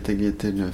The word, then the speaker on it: tagliatelles: Je fais des spaghettis et des tagliatelles fraîches aux œufs. – Hein ? Je prononce pas le G. Tagliatelles.